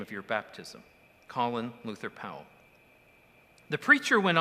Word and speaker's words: Colin: You know, it was interesting to hear that the family always called him Colin, but his pronunciation was changed to Colin while he was serving in the military, when a great military hero whose first name was pronounced Colin was there.